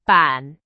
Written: 板

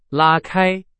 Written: 拉开

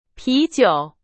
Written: 啤酒

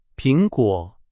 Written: 苹果

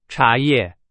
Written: 茶叶

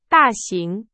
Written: 大型

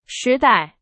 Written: 时代

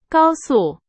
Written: 高速

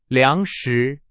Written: 粮食

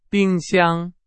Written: 冰箱

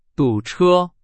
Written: 堵车

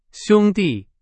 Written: 兄弟

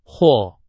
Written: 火

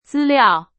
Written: 资料

